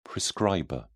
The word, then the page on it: prescriber: British English